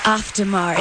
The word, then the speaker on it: Auf der Maur: So am I saying it right? – Oh, yes. – Auf-Demar. It's actually Auf-Demar is a little better, but that's fine.